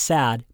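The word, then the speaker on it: sad (сад): They also added in a few doodads and oddballs of their own invention for good measure, such as the final morpheme in a word that looks like C-A delta, pronounced sad but meaning garden.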